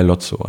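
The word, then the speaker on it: Lowtzow: Der erste Song ist Alles zieht vorbei mit Dirk von Lofzo. spricht man ihn so aus? – Äh, Lotzo einfach. Lo, Lotzo. Aber er hat sehr viele Ws in seinem Namen.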